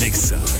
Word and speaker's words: Exa: Después del corte continuamos con más de Raúl Márquez Remix en Exa FM 101.9. No se dicen éxitos, se dice Exa.